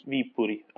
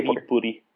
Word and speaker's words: Viipuri: Yes, Viipuri. Okay, Viipuri.